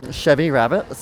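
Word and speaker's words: Chevi Rabbit: Yeah, Chevy Rabbit. So it's spelled C-H-E-V-I and then Rabbit like R-A-B-B-I-T.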